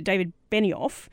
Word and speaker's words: David Benioff: Hey, I noticed, I think you mentioned it, and forgive me if I say it wrong, is it David Benioff? – Yes.